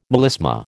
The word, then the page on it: melisma: American English